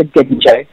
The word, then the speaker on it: ginjo: And I mean, this is the one that's really hard for Australians, because most people see G-I in and they call it ginger, because gin is just so prevalent in our world. But it's actually, the Japanese pronounce that with a hard G, so it's a ginjo.